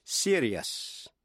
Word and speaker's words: serious: Còn nếu trước những tiếp vĩ ngữ I-O-U-S không phải là phụ âm C, T hay X, thì đuôi I-O-U-S phát âm thành hai âm tiết, tức là hai syllables, ears. Thí dụ, serious, serious.